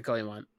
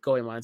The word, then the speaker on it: Goemon: Nope, Goemon is how they say it in the movie.